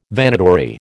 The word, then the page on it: Vanitory: American English